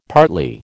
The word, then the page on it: partly: American English